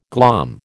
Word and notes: glom: American English

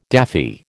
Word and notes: daffy: American English

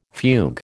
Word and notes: fugue: American English

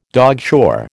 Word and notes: dogshore: American English

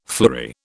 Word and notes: fleury: British English

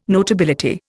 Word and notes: notability: Indian

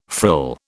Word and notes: frill: British English

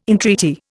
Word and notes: entreaty: Indian

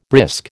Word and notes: brisk: American English

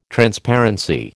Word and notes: Transparency: American English

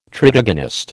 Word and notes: tritagonist: American English

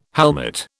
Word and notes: helmet: British English

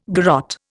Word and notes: garotte: Indian

